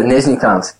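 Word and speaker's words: Nizhnekamsk: I've tried the name of the first team. I cannot for the life of me get the pronunciation right. Let's see if you can remember it. What was the name of the team you played for? The Russian team? – Yeah, Nizhnikamsk. – There you go.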